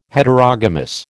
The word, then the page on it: Heterogamous: American English